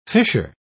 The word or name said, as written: fissure